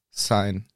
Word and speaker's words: Syne: Schein wird S-Y-N-E geschrieben. – Ah. – Also Sine. – Ah, vielleicht wird das dann gar nicht Schein ausgesprochen. – Sondern? – Sein.